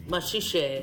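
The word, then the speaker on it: Maxixe: This track, for anyone who wants to know, is called Majij, I think is how you say it. Majij Samba Groove, from an album of the same name. – Machiche. – Machiche. – Yeah, because like, the Portuguese is like different.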